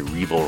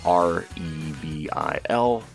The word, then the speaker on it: REvil: So first off, I've got an update for the Reval ransomware. And that's spelled R-E-V-I-L. Some people pronounce it R-Evil. I think it's just kind of interesting to call it Reval.